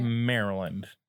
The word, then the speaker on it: Maryland: So technically, yes, but that's not how you say it. Maryland.